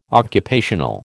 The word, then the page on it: occupational: American English